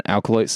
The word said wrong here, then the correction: Acolyte: stuff, Acolyte, however the fuck. There's not an L in that. I've always said that wrong.